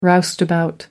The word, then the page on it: roustabout: American English